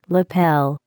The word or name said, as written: lapel